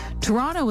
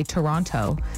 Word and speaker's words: Toronto: Toronto was number one, actually, because they're saying people obviously say Toronto. And it should be Toronto. – Toronto. – It should be soft and not the hard T Toronto. – At the end. – T-U-H-R-O-N-N-O.